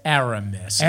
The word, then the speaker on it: Aramis: How do you say it? – Aramis. – Aramis? – No, you said it right, but fucking Aramis? – Aramis.